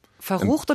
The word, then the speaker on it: verrucht: Verrucht oder verrückt? – Verrucht. – Verrucht? – Verrucht.